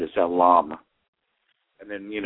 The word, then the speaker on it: lam: It's actually spelled L-A-M, but it's pronounced lam.